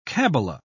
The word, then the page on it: kabala: British English